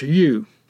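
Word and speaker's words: Yu (豫): And whenever you see this character, it's pronounced Yu, but it stands for Henan.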